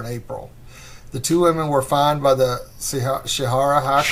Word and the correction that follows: Sharia: Sharia. It's Sharia law.